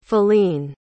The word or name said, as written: faline